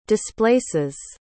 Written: displaces

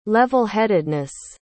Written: levelheadedness

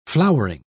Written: flowering

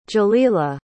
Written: jalila

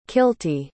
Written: kilty

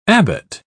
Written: abbot

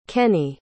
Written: kenny

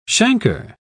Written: chancre

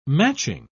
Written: matching

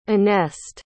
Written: anest

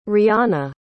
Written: rihana

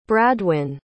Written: bradwin

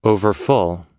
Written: overfull

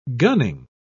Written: gunning

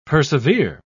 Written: persevere

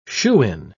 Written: shoo-in

